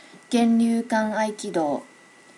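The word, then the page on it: genryukanaikido: native Japanese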